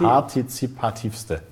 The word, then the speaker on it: partizipativste: Partizipi Parti Partizipativste. Partizipiz Sagst du bitte. Das partizipativste Format wolltest du sagen.